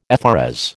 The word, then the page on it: FRS: American English